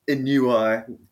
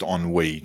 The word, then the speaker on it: ennui: And we're back after a long hiatus filled with work commitments and genuine ennui, etc. Fuck, it's been... – I think it's pronounced ennui.